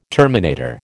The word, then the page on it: terminator: American English